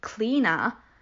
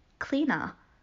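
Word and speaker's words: Clíodhna: So it's pronounced cleaner, apparently.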